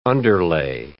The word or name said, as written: underlay